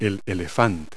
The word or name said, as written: elephant